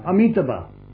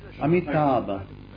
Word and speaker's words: Amitabha: Ah, Amitabha. Yes. Amitabha.